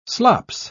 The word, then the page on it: slops: British English